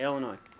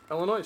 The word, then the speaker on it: Illinois: So, So, Lee, how do you say the name of the state that you're currently in? – Indiana? – No. – this state. Okay, Illinois. – Illinois, see? Illinois.